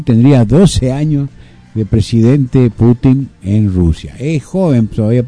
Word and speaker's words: Putin: Acá dicen Putin, pero no sé si es Putin o no, pero el nombre, el apellido en ruso es Putin, en realidad con acento en la U.